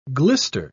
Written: glister

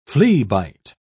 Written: fleabite

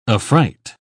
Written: affright

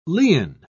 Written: lien

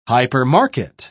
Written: hypermarket

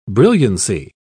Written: brilliancy

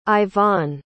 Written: Ivonne

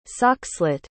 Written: soxhlet

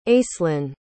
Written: aislinn

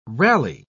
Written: rally

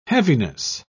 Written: heaviness

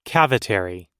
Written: cavitary

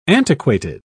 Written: antiquated

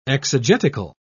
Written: exegetical

